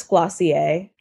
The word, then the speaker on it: Glossier: I've been told that it's Glossier. – Yeah, that's right. – You're not supposed to pronounce the R.